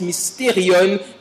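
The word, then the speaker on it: mysterion (μυστήριον): Le mot mystère ici est un mot grec que l'on prononce mysterion, qui veut dire une connaissance réservée à une élite, des personnes choisies.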